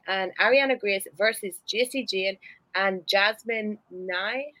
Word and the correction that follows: Nyx: have I said that right? – Jasmine Nyx. – Nyx. – Yes. – Oh, like the cosmetics.